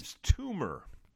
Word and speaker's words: Yves Tumor: Eve's, Yves Tumor.